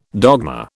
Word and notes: dogma: British English

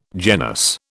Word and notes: genus: British English

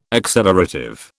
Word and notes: accelerative: British English

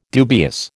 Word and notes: dubious: American English

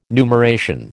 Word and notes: numeration: American English

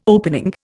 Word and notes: opening: Indian

